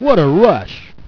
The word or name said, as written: rush